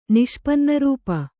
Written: ನಿಷ್ಪನ್ನರೂಪ

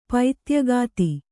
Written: ಪೈತ್ಯಗಾತಿ

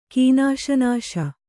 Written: ಕೀನಾಶನಾಶ

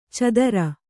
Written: ಚದರ

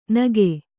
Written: ನಗೆ

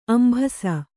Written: ಅಂಭಸ

